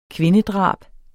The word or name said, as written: kvindedrab